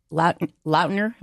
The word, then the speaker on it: Lautner: And but real quick correction, is that Taylor Lautner? Am I saying it right? – Yeah.